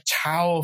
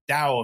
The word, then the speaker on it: Tao: the Tao of Physics, you know, or Tao of Physics, as it should be pronounced, you know, just soaking up all of this stuff.